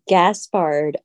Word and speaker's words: Gaspard: It's actually Gaspard with a G. G-A-S-P-A-R-D. It's French.